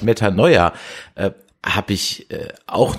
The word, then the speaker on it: Metanoia: Meta, Wird Meta, es wirklich Metanoa ausgesprochen? – Oder ich würde Metanoia, eher Metanoia sagen. Mit, Metanoia, genau.